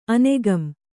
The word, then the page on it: ಅನೆಗಂ: anegam